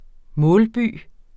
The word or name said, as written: målby